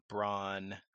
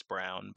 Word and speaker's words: Braun: I mean, one team in Kansas lost Akbaji and Braun, or I guess it's Brown, but it's spelled Braun, and McCormick.